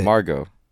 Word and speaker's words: Margot: I think that is not how you pronounce her name. – Yeah, you put a crazy emphasis on the Mar. – Margot? – Yeah, it's not. It's not. – How do you pronounce it? – Margot. – Oh, Margot.